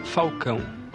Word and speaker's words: Falcão: Il nome mio si pronuncia Falcão, però è come il tifoso romanista me eh, mi chama de Falcao, A adesso mi chiama Falcao.